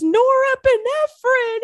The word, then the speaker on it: norepinephrine: So yeah, I I got a bunch of uh, voice memos from you guys going, girl, it's norepinephrine. It's norepinephrine.